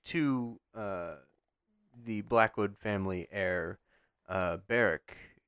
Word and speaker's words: Beric: Thankfully, this Barrick is spelled B-E-R-I-C.